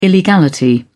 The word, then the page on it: illegality: British English